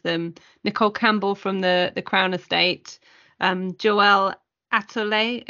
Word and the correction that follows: Alatole: if I pronounced that wrong, I apologise. – Alatole, yeah. Thank you, Alatole, from Cushman and Wakefield.